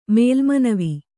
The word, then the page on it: ಮೇಲ್ಮನವಿ: mēlmanavi